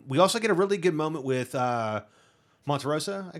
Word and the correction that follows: Montrose: I guess. Am I saying that correctly? Yeah. You know? – Montrose. – Sorry.